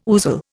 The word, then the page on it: ousel: Indian